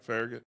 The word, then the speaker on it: Farragut: Are we saying that right, Farragut? – Man, that's right.